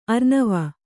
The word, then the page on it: ಅರ್ಣವ: arṇava